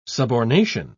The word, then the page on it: subornation: British English